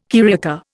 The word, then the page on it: carioca: Indian